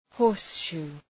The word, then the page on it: horseshoe: ‘hɔ:rs,ʃu: